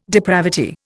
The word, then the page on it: depravity: Indian